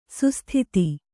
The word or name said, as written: ಸುಸ್ಥತೆ